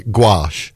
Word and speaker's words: gouache: We say gouache.